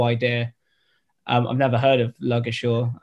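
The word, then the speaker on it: Ludgershall: We've got a team coming along called Luggershaw. We have to pronounce it that way.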